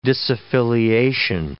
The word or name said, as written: disaffiliation